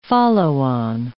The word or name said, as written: follow-on